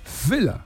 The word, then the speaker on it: Völler: Y acabado en ER no era E, era A. Entonces, lo que tú decías, Rudi Völler, que todo el mundo dice, ahí está Rudi Völler, era Rudi, porque lo pronunciaba así, Rudi, Fela.